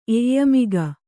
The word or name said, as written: ಎಯ್ಯಮಿಗ